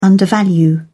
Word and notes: undervalue: British English

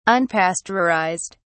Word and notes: unpasteurized: British English

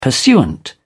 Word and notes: pursuant: British English

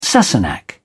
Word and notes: sassenach: British English

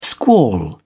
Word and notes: squall: British English

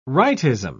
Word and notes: rightism: British English

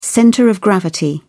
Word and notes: centre of gravity: British English

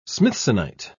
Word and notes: smithsonite: British English

